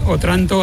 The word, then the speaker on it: Otranto: ¿Qué tiene Otranto, aparte de ese lugar geográfico importante? – Otranto, con el acento en la O, tiene ese lugar por dentro de historia, de arte, realmente muy atrás en el tiempo.